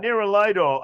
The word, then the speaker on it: Nerolidol: Neuralidol or Nerolidol. – Oh, yeah. Similar. They're similar things. – You put the accent on the wrong syllable. – Nerolidol.